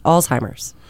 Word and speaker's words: Alzheimer's: Look at the spelling. – I did. – There's no T's. – But when you hear people say it, and it doesn't sound like it's spelled. – Because everybody's saying it wrong, but it's Alzheimer's.